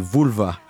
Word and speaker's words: Vulva: Et donc, je vous le disais en introduction, ce soir, c'est Flo, Tiff Lam et Vulva Vendetta. Je ne sais pas si je le dis bien, je dis Vulva.